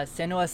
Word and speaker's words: Senua's: But you know, with you talking about that, it's kind of given me a lot of vibes of Hellblade, Suena was, or however you say her name, Sacrifice. – Senua's Sacrifice.